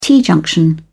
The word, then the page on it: t-junction: British English